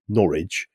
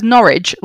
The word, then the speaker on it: Norwich: She says Norwich like porridge and it's really annoys me. – Norwich.